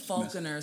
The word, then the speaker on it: Falconer: I don't want to Falconer. So it's like the bird Falcon, ER at the end.